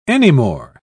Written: anymore

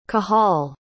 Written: cahal

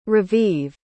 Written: raviv

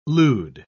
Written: lewd

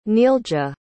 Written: neelja

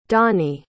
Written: donnie

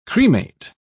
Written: cremate

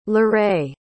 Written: larae

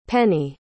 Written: pennie

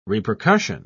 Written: repercussion

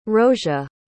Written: rosia

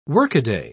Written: workaday

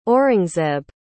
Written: aurangzeb

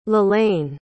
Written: Lalaine

